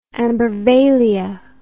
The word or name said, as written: ambarvalia